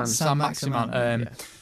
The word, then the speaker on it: Saint-Maximin: And Phil, who obviously does the podcast as well, quite regular, big Newcastle fan, he showed me a video of Saint-Maximin. Is that how you say it? – Saint-Maximin.